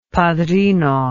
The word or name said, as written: padrino